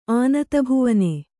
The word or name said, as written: ಆನತಭುವನೆ